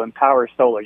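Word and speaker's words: EMPWR Solar: Just Yeah, we dropped some vowels there. E-M-P-W-R. So Empower Solar. You got it right.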